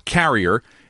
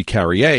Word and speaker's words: Carrier: Rock Carrier, and you might say, well, he must be Carrier because he's from Quebec, but he actually pronounces it Carrier,